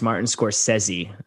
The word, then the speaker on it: Martin Scorsese: And they clarified that it is actually pronounced Martin Scorsese. And they made a big deal about that, the Italiano pronunciation.